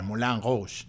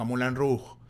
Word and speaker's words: Moulin Rouge: Yo te lo pronuncié como la puta madre, espectacular, Moulin Rouge, ¿entendés? El pibijo, Moulin Rouge.